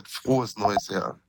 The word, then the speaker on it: Frohes Neues Jahr: Dennis, we wanted to start by saying, Froy Noy Yar. How was that? – Wasn't that bad. Frohes Neues Jahr. – Okay. Yeah. – That's how you say it.